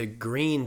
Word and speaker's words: Gruene: She had been to New Braunfels before, and we went to Green, Texas, which is spelled G-R-U-E-N-E, which is how you say green in German, Grün.